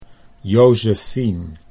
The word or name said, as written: Jozefien